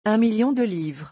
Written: un million de livres

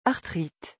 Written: arthrite

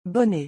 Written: bonnet

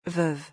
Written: veuve

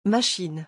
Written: machine

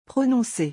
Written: prononcer

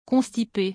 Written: constipe

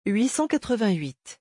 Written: 888

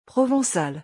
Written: provencal

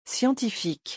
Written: scientifique

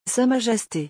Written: sa majeste

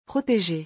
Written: proteger

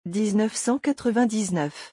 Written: 1999

